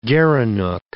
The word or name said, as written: gerenuk